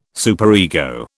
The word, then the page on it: superego: British English